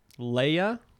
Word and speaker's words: Leah: Leia? – It's spelled Leah, L-E-A, but it's pronounced Leia.